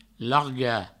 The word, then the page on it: largar: larga; Provençal